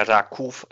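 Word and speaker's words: Raków: Beziehungsweise es heißt ja Rakuf eigentlich im Deutschen, ja. weil es ja ein O mit einem Strich oben drüber ist, wird ja als U ausgesprochen ist ja sozusagen in der Extraklasse eine Überraschungsmannschaft gewesen, oder?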